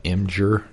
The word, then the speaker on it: Imgur: Reddit, and if you dig deeper than that, Imgur, if I'm pronouncing that correctly.